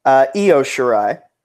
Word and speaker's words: Io Shirai: And then, so after the Battle Royal, we move on to the Mae Young Classic 2018 finals as Io Shirai. Did I say that correctly? – Io Shirai.